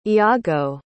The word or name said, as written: iago